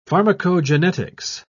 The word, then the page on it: pharmacogenetics: British English